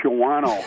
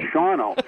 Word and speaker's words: Shawano: It was Shawano because my friends would look at it on the map and they'd all say, oh, you're going up to Shawano. And I'm like, no, it's Shawano.